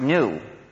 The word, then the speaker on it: GNU: You see, the reason why the word GNU is used for so many jokes is that according to the dictionary, it's supposed to be pronounced new, just like the word for something that's the opposite of old, right?